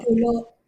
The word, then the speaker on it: pulo: Root word pulo means island. – So pulo, like that's how you pronounce pulo.